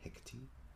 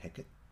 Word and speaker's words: Hekati: It has a new version of a new commission, never before heard of the Scottish play done all in Noongar language called Hekati, or as it's pronounced in Noongar, Heket, named after the witch at the centre of that Shakespeare play.